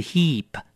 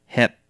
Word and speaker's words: hip: 第五个字，比如说像是臀部，不是 heap, 是 hip, hip, 像下巴是 chin, chin, 那么在念的时候要注意了，一定要多一点点的鼻音 chin, 因为是一个 n 结尾。